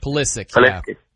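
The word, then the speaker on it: Pulisic: And they're going to look different with Polisic. Hopefully I said his name right. Polisic. – That's the name, right? – Polisic. Yeah.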